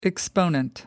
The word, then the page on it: exponent: American English